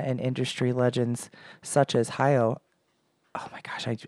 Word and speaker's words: Hayao: But I know how to say his name. It's Miyazaki is his last name. – Oh, Hiro Miyazaki. – It's Hayao. Hayao.